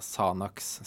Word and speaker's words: Xanax: Men denne X-en, eller den KS-en foran i navnet, hvordan skal vi uttale den? Mener du? – Jeg mener vi går for S, eller en Z-lyd, altså Sanor. Ja, ok. Eh, Sanax, Sanor, ja. eh, som i liksom Altså xylofon, da, for mm.